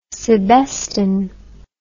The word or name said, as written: sebesten